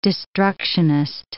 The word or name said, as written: destructionist